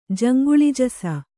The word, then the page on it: ಜಂಗುಳಿಜಸ: Kannada